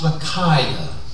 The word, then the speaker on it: Micaiah: The Hebrew name there is not the shortened version of Micah, but the longer version of his name that I would pronounce Micaiah.